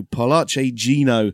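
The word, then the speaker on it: Parlaci Gino: Let's talk about, first of all, Paolucci Gino. I think I've pronounced that correctly after Chris Giannone corrected us on Spaces the other day. So Paolucci Gino is a hashtag which translated means talk to us Gino.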